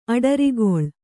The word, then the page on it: ಅಡರುಗಾಲು: aḍarugālu